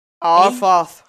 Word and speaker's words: Auffarth: Auffarth, who art in heaven. – A-U-F-F-A-R-T-H.